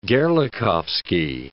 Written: Gerlachovsky

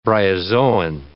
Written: bryozoan

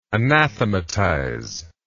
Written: anathematizer

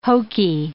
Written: hokey

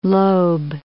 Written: Loeb

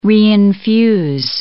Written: reinfuse

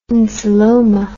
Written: insuloma

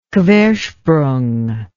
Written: quersprung

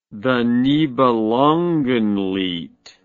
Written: nibelungenlied the

